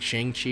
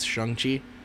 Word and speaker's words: Shang-Chi: so it's not Shang-Chi, it's Shang-Chi, so that's how you're supposed to pronounce it.